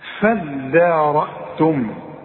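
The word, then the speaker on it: فادارأتم: نفسا فدارأتم، دي عايزة منك فن في النطق، ما تقولش فض الدال، الدال والمد بتاعها مرقق، هتقول فدان، عارف على وزن ايه فدان، شوف فدان ارض، فدان، شوف الدال، هتقول لي ايه فضان ولا تقول لي فدان؟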